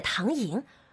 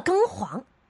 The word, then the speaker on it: 唐寅: 薛蟠不学无术，把明朝四大才子之一的唐寅读成了庚黄，白字先生一个。